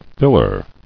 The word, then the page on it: Filler: fill·er